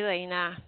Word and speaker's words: Lana: Is it Lena or Lana? – Um, it is actually Lena.